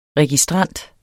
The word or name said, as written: registrant